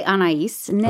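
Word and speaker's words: Anaïs Nin: Is that how you say that? – I say Anais Nin.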